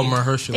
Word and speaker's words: Mahershala: Oh, Mahershala.